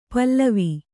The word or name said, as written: ಪೇಲವ